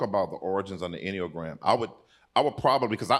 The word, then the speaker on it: Enneagram: Yeah, I think for me, so I'm studying this right now, particularly the Enneagram, if that's how you say it. – Enneagram. – Enneagram, okay.